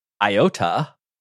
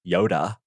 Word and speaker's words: Iota: Eta was a category 4 that struck Nicaragua, and then 2 weeks later, Iota, which I think is pronounced like Yoda in Greek, Yoda, Anyway, Yoda came and struck Nicaragua as a Category 5 in the same spot.